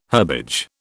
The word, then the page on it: herbage: British English